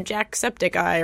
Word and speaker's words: Jacksepticeye: I mean, these are like somewhat popular, but they're not like necessarily like a PewDiePie or a Jacksepticeye or whatever his name is.